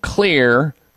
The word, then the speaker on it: Xlear: And the one that I use personally and recommend is something called Clear Rescue Nasal Spray. Clear is spelled X-L-E-A-R.